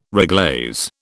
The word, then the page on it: reglaze: British English